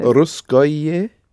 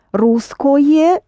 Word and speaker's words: русское: Русское радио? – Of course. Русское, if you pronounce it syllable by syllable, but o is not pronounced as o, but close to a, so...